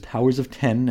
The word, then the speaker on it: Powers of X: Is the name of the series pronounced Powers of X or Powers of Ten? Well, of course, it is powers of 10, and I will never refer to it as such.